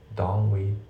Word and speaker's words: don't: して発音なんだけど、 don't のその t がね抜けますよね。 don't we、 don't we ってなって don't we ですね。